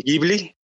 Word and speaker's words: Ghibli: En realidad, Ghibli se pronuncia en italiano, que es la palabra de origen. El país de origen se pronuncia Ghibli, pero en Japón, pues la grafía la toman de los sonidos del inglés y para ellos fue Ghibli, Ghibli, para tratar de acoplarlo a la pronunciación japonesa.